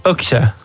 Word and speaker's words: Okse: A na koniec Okse. Czy dobrze to wymawiam? – Tak, Okse.